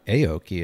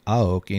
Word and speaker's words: Aoki: Yeah, and it's not Aoki, it's Aoki, and he doesn't want to be called Nori anymore. – Oh. – So it's Aoki, not Aoki.